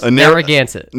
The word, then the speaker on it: Narragansett: So I'm looking at a Narragansett. – Very close. Narragansett.